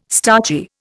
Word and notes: starchy: Indian

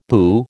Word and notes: boo: American English

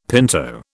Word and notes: pinto: British English

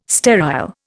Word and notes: sterile: Indian

